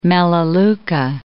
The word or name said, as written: melaleuca